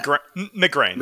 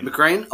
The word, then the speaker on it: McGrain: Is it McGrain, McGran? How do you pronounce the last name? – McGrain. – McGrain? Oh, okay, awesome.